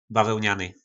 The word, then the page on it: bavelnany: po nasimu